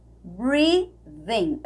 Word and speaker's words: breathing: Eu coloquei um Z aqui na pronúncia desse TH tá? Você vai falar como um Z, mas com a língua entre os dentes. Breathing in your dust. Na verdade, fica entre um V e um Z esse, esse som, né?